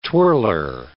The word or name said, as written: twirler